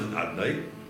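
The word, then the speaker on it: Adnai (אדני): You know, Chazal says, Mar Psochem Davnun, in this world, the Eberster is written as Shem Avaya, and it's pronounced Adnai.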